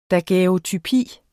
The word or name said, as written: DAGUERREOTYPIERNES